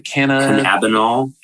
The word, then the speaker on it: Cannabinol: Cannabinol. – Cannabinol, a substance not classified under Massachusetts law, but not THC.